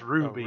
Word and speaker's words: RWBY: R-W-B-Y? – It's pronounced Ruby. – Oh, Ruby. – Yeah. – Spelled R-W-B-Y. – Okay. That was so Ruby, spelled R-W-B-Y.